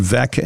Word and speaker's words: Veeck: And he had a book, I don't know whether it's his autobiography or a biography about Bill Veeck, that said Veeck as in wreck. So the pronunciation was right there. The guy's name was spelled V-E-E-C-K.